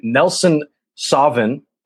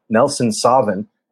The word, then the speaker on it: Nelson Sauvin: And Nelson Sauvin, and I think that's how you say it. It's like Sauvignon. Nelson Sauvin, which is also out of, I believe that's New Zealand.